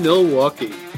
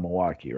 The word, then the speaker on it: Milwaukee: Yeah, born in Milwaukee, and you don't pronounce the L. It's just Milwaukee.